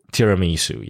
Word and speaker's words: tiramisu: Tiramisu. Is the right pronunciation? – Tiramisu, yeah.